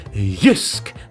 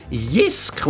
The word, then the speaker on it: Jysk: Das korrekte Jysk. Nicht Jysk und auch nicht Jusk, sondern etwas zwischendrin. Irgendwann hat es dann Linda Fey geschafft, richtig schön Jysk zu sagen.